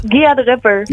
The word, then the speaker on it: Gia de Rapper: De volgende die op de lijst staat is volgens mij, ik vind een hele bijzondere naam, Guide Rapper, zeg ik dat goed? – Gia de Rapper.